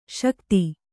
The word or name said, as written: ಸೈಕತ